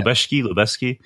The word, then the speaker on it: Lubezki: So, Emmanuel Lubezki. – I can never figure out how to pronounce his name. Lubezki, right? Yeah. Lubezki.